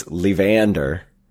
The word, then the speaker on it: Levander: so it's Levander, sort of like Evander Holyfield. And I think the 22 is because he was undefeated in his first 22 fights, so that's why it's Levander's 22.